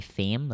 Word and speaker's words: Pham: like okay, my my first name is Tuan, like T U A N, and my last name is Fam, like P H A M.